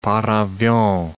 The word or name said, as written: par avion